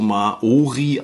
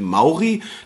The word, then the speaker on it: Maori: Also die, eigentlich ist es so, dass es gar nicht so Maori ausgesprochen wird, so wie wir das jetzt immer sagen, sondern es sind die Maori. Das O ist irgendwie stumm und diese Jungs, ähm, die leben auf Neuseeland und sind sozusagen die Indianer von Neuseeland.